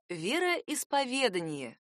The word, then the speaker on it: вероисповедание: Основное ударение падает на второе «ве», а второстепенное — на первый слог. Получается «вероисповедание».